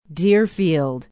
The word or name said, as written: Deerfield